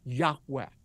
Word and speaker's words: Yahweh: Everybody from Moses on refer to him as Yud-Heh-Vav-Heh, and most Semitic scholars believe it's pronounced a breathy Yahweh.